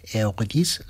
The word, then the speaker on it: Eurydice: Lo he pronunciado la italiana antes, pero es el Eurydice, la versión francesa del Orfeo de Eurídice de Gluck.